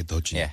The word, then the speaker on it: 해돋이: So the first sunrise, well, we don't really have a term for the first sunrise, but sunrises in general is 해돋이. – 해돋이. – You write it as 해돋. E, but you pronounce it, you know, more fluidly. – 해도지. – Yeah, 해도지.